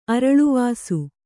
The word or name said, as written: ಅರಳ್ವಾಸು